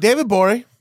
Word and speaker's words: David Gborie: David Gborie. – Yes, sir. – At the G is silent on Twitter.